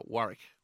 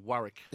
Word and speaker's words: Wurruk: Warwick. – Warwick? – Yep. W-U-R-R-U-K. – Sorry, what is it? – Warwick. – How do you spell it? – W-U-R-R-U-K.